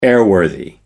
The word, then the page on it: airworthy: American English